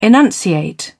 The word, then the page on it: enunciate: British English